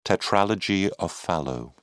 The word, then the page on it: tetralogy of fallot: British English